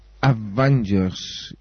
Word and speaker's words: Avengers: Het is eigenlijk niet helemaal goed uitgesproken. – Avengers dan misschien?